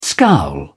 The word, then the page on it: scowl: British English